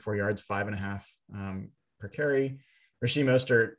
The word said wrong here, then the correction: Raheem Mostert: uh, Raheem Mostert, I'm gonna get his name right. Raheem Mostert, uh, two carries for 20 yards um, before he went out with a knee injury.